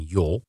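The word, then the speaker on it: jol (ё): Want een E zonder puntjes, die spreek je weer uit als yeah in plaats van jol.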